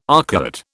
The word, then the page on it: arcuate: British English